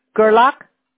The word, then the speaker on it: Gerlach: G-R-L-A-C-H. – G-R-L-A-C-H? – No, G-E-R-L-A-C-H. – Oh, G-E-R. Okay. Gerlach? Is that how you pronounce it? – Gerlach, right. Yeah, okay. Right. G as in George, E-R. – Right. L-A-C-H.